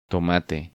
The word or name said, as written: Tomate